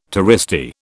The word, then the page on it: touristy: British English